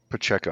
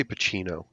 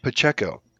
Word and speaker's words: Pacheco: Pacheco. – I always want to say Pacino. Yeah, Pacheco. Again, did great.